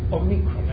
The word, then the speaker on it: Omicron: The short O is O, as in got, and it's called Omicron, as in microscope. Omicron, the short O, as in pot, or got, or lot.